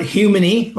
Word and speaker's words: Humoni: I call it Humoni, like human with a Y.